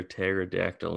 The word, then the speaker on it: pterodactyl: Hey, why can't you hear a pterodactyl in the bathroom? – Why can't you hear a pterodactyl in the bathroom? – Because the P is silent.